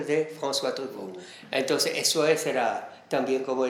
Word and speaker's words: Truffaut: François Truffaut.